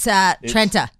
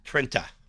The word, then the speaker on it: Trenta: It's Trenta. – Trenta. – Trenta. – Oh, I thought it was Tetra.